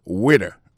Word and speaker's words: waiter: Alors moi je prononce le waiter. Waiter ça veut dire accent tombé sous premier syllabe là waiter W A I T E R.